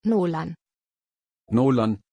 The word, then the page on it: Nolann: German